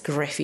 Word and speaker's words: Gruff: I think it's Griff, even though it's spelled Gruff, and it's called Seeking New Gods.